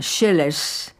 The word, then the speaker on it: Sileas: El dúo Sileas, que se pronuncia Schellers y que también es dúo de arpas, interpreta este Patzabul.